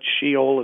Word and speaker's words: Sheol: It's a Hebrew word. Sheol is how it's pronounced.